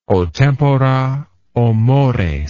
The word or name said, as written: o tempora o mores